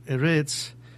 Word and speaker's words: Eretz: Israel, que se pronuncia Eretz, como se dice comúnmente en la transliteración hebrea.